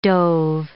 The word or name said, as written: dove